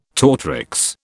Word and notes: tortrix: British English

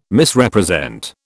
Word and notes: Misrepresent: British English

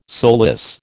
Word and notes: soulless: American English

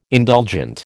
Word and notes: indulgent: American English

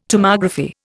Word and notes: Tomography: Indian

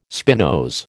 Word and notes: spinose: American English

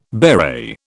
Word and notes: beret: British English

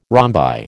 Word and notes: rhombi: American English

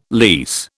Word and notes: lease: British English

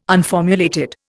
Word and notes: unformulated: Indian

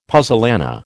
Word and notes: puzzolana: American English

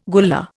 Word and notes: Gullah: Indian

